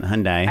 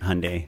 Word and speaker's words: Hyundai: I've pitched on Hyundai, which is why I know it rhymes with Sunday. And it's not Hyundai.